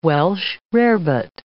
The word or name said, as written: Welsh rarebit